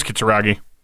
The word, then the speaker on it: Kitsuragi: And I know I pronounced it wrong. I think it's Katsunagi. – And I think you were right. I think it is Kitsuragi.